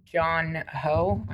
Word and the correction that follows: Bong Joon Ho: Bo- Bong Joon Ho.